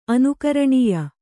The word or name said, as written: ಅನುಕರಣೀಯ